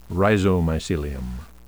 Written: rhizomycelium

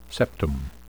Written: septum

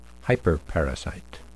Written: hyperparasite

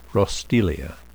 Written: roestelia

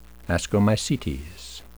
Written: ascomycetes

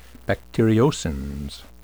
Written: bacteriocins

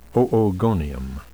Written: oogonium

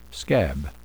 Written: scab

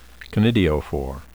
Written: conidiophore

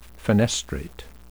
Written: fenestrate

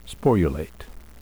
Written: sporulate

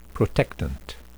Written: protectant

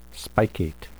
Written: spicate

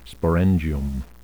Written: sporangium

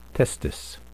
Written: testis

